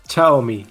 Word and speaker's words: Xiaomi: cómo lo dicen ellos? – a ver, venga. Ellos dicen, según el vídeo que pusieron, era Xiaomi.